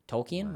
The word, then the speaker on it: Tolkien: Tolkien? Why did I say it like that? – Because that's how it's supposed to be.